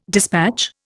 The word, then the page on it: despatch: Indian